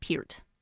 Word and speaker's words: Peart: I thought it was Neil Peart for all these years. – I think years. – Most people say Peart because that's the way it's kind of spelled, but it is technically Neil Elwood Peart.